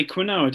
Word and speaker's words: quinoa: Oh, I bet you say quinoa, don't you? It's pronounced quinoa, even though it's about quinoa.